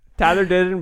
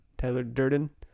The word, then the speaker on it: Tyler Durden: Tyler Durden would be proud. – Yeah. – Butchered that. Durden. Tyler Durden.